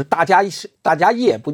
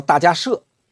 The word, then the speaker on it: 大迦叶: 叫迦摄佛，就是大迦叶，不叫大迦叶。他正确的发音叫大迦摄，所以这个佛叫迦摄佛，就是我们通常说的燃灯古佛，他是在那儿修成得道的。